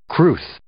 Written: crwth

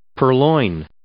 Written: purloin

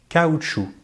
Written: caoutchouc

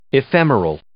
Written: ephemeral

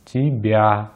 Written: тебя

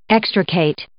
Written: extricate

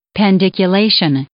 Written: pandiculation

